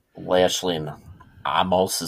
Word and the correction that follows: Omos: is that how you say his name? – Omos, sorry.